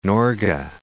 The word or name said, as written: Norge